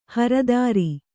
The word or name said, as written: ಹರ್ತಾರ